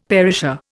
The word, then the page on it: perisher: Indian